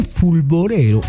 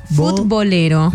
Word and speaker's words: futbolero: El chisme fulborero. A ver, lo dije bien. – Fulbolero. – Futbolero.